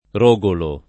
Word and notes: Rogolo: italiana

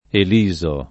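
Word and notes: Eliso: italiano

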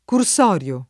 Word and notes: cursorio: lingua italiana

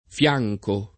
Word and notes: fianco: lingua italiana

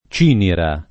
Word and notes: Cinira: lingua italiana

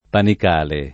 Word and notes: Panicale: lingua italiana

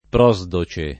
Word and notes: Prosdoce: lingua italiana

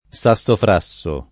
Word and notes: sassofrasso: lingua italiana

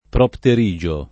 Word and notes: propterigio: lingua italiana

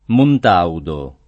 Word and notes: Montaudo: lingua italiana

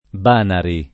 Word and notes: Banari: lingua italiana

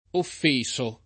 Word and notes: offeso: lingua italiana

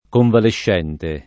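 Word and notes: convalescente: italiano

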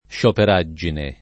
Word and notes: scioperaggine: lingua italiana

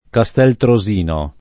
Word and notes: Castel Trosino: Italian

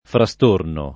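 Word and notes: frastorno: italiano

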